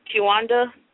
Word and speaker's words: Quewanda: Kiwanda, or you can call me Q, but you spell it Q-U-E-W-A-N-D-A.